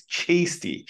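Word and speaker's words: Cheasty: I know it says Chasty, but that's apparently how you say it. It's Chasty.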